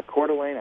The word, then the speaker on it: Coeur d'Alene: Coeur d'Alene or something? – Yeah, Coeur d'Alene, Idaho.